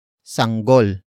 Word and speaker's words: sanggol: And that is sanggol. Sanggol. Sanggol. Sanggol is spelled as S-A-N-G-G-O-L.